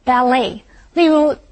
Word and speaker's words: ballet: 今天的第一个重点我们要来看的是 ballet 这个不可数名词。Ballet 就是指芭蕾舞，注意它的重音在第二个音节。而且字尾的 T 是不发音的，念成 ballet。例如